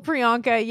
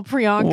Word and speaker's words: Priyanka: Like good old Priyanka. Yeah, it's the nice three-syllable Priyanka.